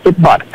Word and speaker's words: C-Bot: เราอ่านว่า C-Bot ครับ